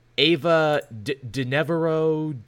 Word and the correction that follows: DuVernay: I can never pronounce her last name. Ava DuVernay. – DuVernay, thank you, is actually developing of all things, a Naomi show for the CW.